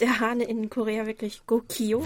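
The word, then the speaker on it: Gogio (꼬끼오): genauer ausgesprochen Gogio. Mhm. – mhm. – Ähm, und eigentlich, wenn man genau hinhört, klingt das auch wirklich so, muss ich sagen. Also ich finde es ähm, Gokio ähm, realitätsnäher als äh, Kikiriki.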